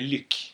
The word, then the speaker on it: Luc: Och egentligen säger man ju Luc.